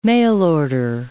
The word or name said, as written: mail-order